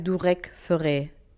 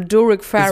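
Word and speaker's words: Durek Verrett: Wie spricht man? Sag nochmal. – Also geschrieben wird der Durek Ferret und ich sage Durand Forel. – Ich würde sagen Durek Ferret. – Okay. – Oder Durek Ferret.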